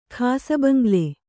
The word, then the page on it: ಖಾಸಬಂಗ್ಲೆ: khāsabaŋgle